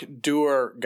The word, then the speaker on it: dour: A dark dwar goth, D-O-U-R. – I thought that was dour. – That's a mispronunciation. It's actually pronounced dwar.